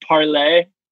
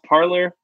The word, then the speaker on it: Parler: We're going to talk about Parler, the right-wing social media app that should just be pronounced Parler, but for some reason they went with the French pronunciation to fuck with their base.